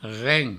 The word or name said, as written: ren